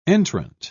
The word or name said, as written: entrant